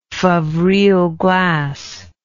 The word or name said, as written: favrile glass